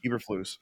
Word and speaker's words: Eberflus: Eberflus.